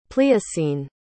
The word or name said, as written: pliocene